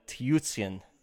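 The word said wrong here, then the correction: Tyutin: It's Fedor Tootin. – Oh, see, I tried to like... – Nah, that didn't work. All right. – Fedor Tootin.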